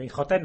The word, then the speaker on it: Imhotep: Sí, se escribe Imhotep con un H intercalada. Y bueno, a saber cómo se diría en egipcio, ¿no? Pero bueno, el Imhotep nos vale, ¿no? Una especie de convención fonética.